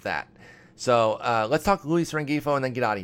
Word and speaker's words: Rengifo: I'm pronouncing the G like a total noob-ass noob, Rengifo.